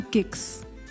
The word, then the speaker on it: Giks: I'm your girl Cynthia, but you know you can call me Gix. And can I just say, Gix is spelled G-I-K-S.